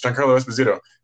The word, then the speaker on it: Giancarlo Esposito: And I just like say his name, and he's like, oh, it's Giancarlo Esposito. And he like looks at me and he's like, yes.